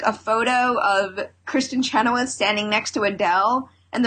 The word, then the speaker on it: Chenoweth: I didn't really watch much of the red carpet, but Kristen Chenoweth. Is that how you pronounce her name? – Chenoweth. – Yes.